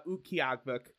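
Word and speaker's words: Utqiagvik: It is now Ukiagvik.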